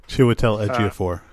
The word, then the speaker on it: Chiwetel Ejiofor: I think, like, Chiwetel Ejiofor. Damn it. Should have learned how to say his name. – Chiwetel Ejiofor. – Thank you.